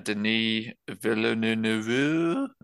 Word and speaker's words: Denis Villeneuve: So this is a Denis Villeneuve movie.